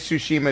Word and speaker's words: Tsushima: but the Ghost of Tsushima, I think, is that how you pronounce it? I don't know if you guys watched... – It is Tsushima, it's going to be amazing.